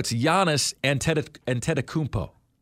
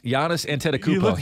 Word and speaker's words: Giannis Antetokounmpo: You can't pronounce his last name either. It's Giannis. – It's Giannis Antetokounmpo. – Are you sure? – Yes, Giannis Antetokounmpo.